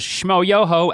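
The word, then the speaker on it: Shmoyoho: Their YouTube channel, known as Shmo Yoho, accent on the yo, was created back in 2006 by Michael.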